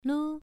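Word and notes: lu: tone 1

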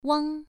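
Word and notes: wang: tone 1

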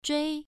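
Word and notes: zhui: tone 1

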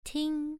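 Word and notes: ting: tone 1

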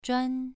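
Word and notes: zhuan: tone 2

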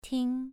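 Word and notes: ting: tone 2

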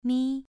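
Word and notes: mi: tone 2

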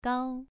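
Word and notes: gao: tone 2

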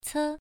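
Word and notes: ce: tone 1

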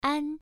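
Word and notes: an: tone 1

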